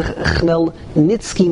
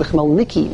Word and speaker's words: Chmelnitsky: the Chmelnitsky massacres, or some pronounce the Chmelnicki massacres, and the Sreifa Satora, and all different forms of tzaras are collected on the Ben Ubas of Rabbi Shmuel Kohen Gadol.